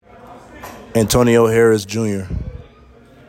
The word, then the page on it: Antoineo Harris, Jr.: an TOE KNEE yo HARE iss